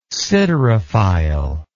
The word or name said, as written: siderophile